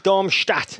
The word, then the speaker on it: Darmstadt: I mean, SV Darmstadt, yeah.